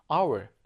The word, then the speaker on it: hour: 이 단어 같은 경우에는 H로 시작이 되는데 H가 발음이 되지 않는 무음이라고 얘기하죠, 여러분들. 그러니까 발음이 hour가 아니라 hour. 그러네요.